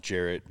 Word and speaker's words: Garrett: And it looks like Garrett on paper, but then it's pronounced Jarrett because my mom wanted me to have a unique name.